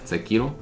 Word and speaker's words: Sekiro: Oh, Sekiro. That's how you say it. Sekiro.